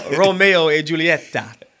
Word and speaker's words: Romeo e Giulietta: It's called Romeo and Juliet. You had it right the first time. They call it Romeo e Giulietta.